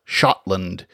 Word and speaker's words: Scotland: Another interesting fact is that the Old English form of Scot indicates that it would have been pronounced like other Old English SC words, that is, with an SH sound. Shot or Shiota, Shotland.